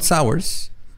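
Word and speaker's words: Sauers: Don Sowers Sí, como... Como ah, ácido, sour. Ah, supongo que se pronuncia, pero se escribe S-A-U-E-R-S. E sours.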